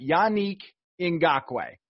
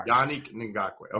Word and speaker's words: Yannick Ngakoue: He started out his press conference by pronouncing his name so everyone had it. Yannick Ngakoue. Not that hard. – Yannick Ngakoue.